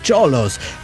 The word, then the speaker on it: Cholos: Número dos, el nombre del equipo de los Cholos, aunque suena como una S, aquí lo pronunciamos con CH, Cholos.